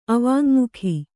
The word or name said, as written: ಅವಾಙ್ಮುಖಿ